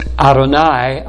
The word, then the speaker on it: Adonai: Though it is pronounced Adonai out of respect and reverence, the word Lord in the original is Yahweh, the personal name of the true God.